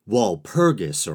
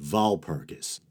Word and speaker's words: Walpurgis: And I suppose I should pause briefly to mention that in English-speaking countries, Walpurgis or Walpurgis seem to be acceptable pronunciations as well. But for this documentary, I've decided to go with the Germanic pronunciation of Walpurgis.